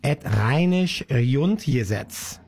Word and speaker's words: Et Rheinisch Grundgesetz: Die Band BAP mit Et Es Wird Es, kölsches Sprichwort, auch zu finden im sogenannten Rheinischen Grundgesetz, Et Rheinisch Grundgesetz. Habe ich wahrscheinlich falsch ausgesprochen. Mein Kollege Wolfgang Müller lacht schon mir gegenüber. Nee, ist wunderbar.